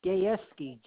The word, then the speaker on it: Gayeski: Yes, it's Frank Gayeski.